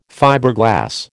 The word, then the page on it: Fiberglass: American English